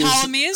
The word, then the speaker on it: Ptolemies: Ptolemies, uh, but very, very, it's Ptolemaic, but Ptolemies, which is very confusing. All right. At least not pronouncing, at least not pronouncing the P, which I've done in the past.